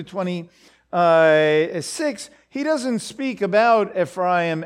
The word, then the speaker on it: Ephraim: Because the tribe of Joseph was divided up between his two sons, Ephraim and Manasseh, which is how you pronounce those names. Ephraim and Manasseh, these two sons.